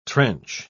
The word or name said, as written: trench